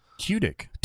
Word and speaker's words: Tudyk: Because he spelled it T-Y. So T-Y, like T-I-C-K. Oh, yeah. – Tudyk. All right.